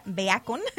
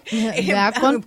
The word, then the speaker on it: Beacon: Se escribe medio raro, pero hagan de cuenta, Beacon. – Beacon. – Como bacon, pero no bacon.